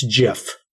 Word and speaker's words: GIF: It's GIF. So a soft J, that's how you are supposed to pronounce it. The Oxford English Dictionary accepts both pronunciations, but in an interview with the New York Times, he was very straightforward on the fact it's a soft G.